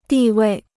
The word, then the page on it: 地位: dì wèi